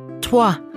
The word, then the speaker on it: toi: OI wird wie Englisch Water ausgesprochen. – Toi, Toilette, Droit.